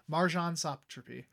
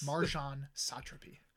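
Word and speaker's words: Marjane Satrapi: Marjan Satrapi. And we said her name right because we looked up an interview where she said her name. – Yes. – Marjan Satrapi.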